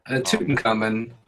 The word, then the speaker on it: Tutankhamun: Tutankhamun. – Tutankhamun.